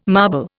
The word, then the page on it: marble: Indian